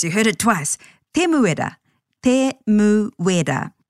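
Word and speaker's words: Temuera: He may actually be often known as Tim, but to pronounce his full name, Te Muera, again, stay true to the vowel sounds, a, e, I, or u, and break the name into syllables, which will always end with a vowel.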